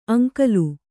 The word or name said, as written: ಅಂಗಲು